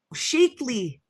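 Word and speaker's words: Shaklee: Shackley. – Shakely. – Shakely? She pronounced it Shackley. – Or maybe, I don't know. I think it's Shakely.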